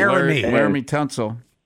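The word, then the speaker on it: Laremy Tunsil: Like, they got the Larry Tunsil, whatever his name. I hope I'm pronouncing his name. – Right? – Laramie Tunsil. – Tunsil.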